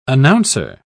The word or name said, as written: announcer